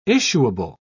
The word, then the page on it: issuable: British English